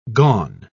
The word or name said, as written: gone